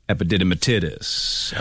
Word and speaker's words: epididymitis: It ended up being epididymitis, or whatever that's called.